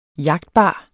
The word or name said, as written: jagtbar